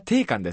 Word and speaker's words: 定款: 会社定金？ – 会社定款ですね。 – 勘って読むんですね。